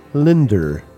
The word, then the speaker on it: Linda: From his solo record, Jeremy Spencer, we heard a song called Linda, or as he pronounces it, Linder.